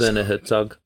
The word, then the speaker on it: Werner Herzog: Is that how you say his name? – Say Werner Herzog. – Werner Herzog.